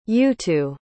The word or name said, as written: yutu